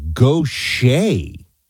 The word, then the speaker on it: Gautier: How to pronounce Gautier, it's Gautier. I'm from Mississippi, Paul says. I would not have gotten this. So that's like a Cajun, like Bobby Boucher or Bobby Gautier. That's a weird pronunciation.